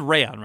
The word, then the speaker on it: Rayon: He's out of the country, he comes back, he realizes that Rayon, He does Rayon, right? We're saying this correctly? – We're saying it right, He realizes that Rayon has died.